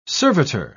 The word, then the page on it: servitor: British English